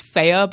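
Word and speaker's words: Fe: It's pronounced Faye, but I just keep calling it Faye because it's spelled F-E.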